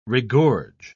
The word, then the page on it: regorge: British English